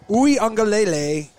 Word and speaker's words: Uiagalelei: Uiagalelei.